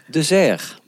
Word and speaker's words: Dezaire: Hoe spreek je je achternaam uit, Nico? – De Zerg.